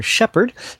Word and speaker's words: SHPHRD: Justin Little from Portland, Oregon performs under the moniker Shepherd, spelled without vowels S H P H R D.